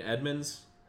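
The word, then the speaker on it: Edmunds: I'm going to say his name right this time. It is Tremaine Edmonds out of Virginia Tech.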